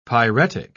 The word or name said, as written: pyretic